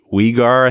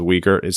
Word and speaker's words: Weegar: Mackenzie Weegar, I think is how they pronounce it. Yep. I always thought it was Uyghur.